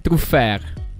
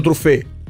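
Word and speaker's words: Truffaut: Traffè. – Vabbè. – Sì, vabbè. – Dai. – Non si dice così? – Truffer. – Vabbè, che hai detto? Truffer ha detto. – Ha detto truffé. Truffé.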